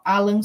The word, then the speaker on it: Alain: que é o Alan Soral, pronunciar para você poder procurar, A-L-A-I-N, Soral,